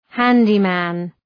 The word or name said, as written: handyman